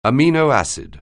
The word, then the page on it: amino acid: anglais